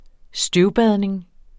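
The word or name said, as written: støvbadning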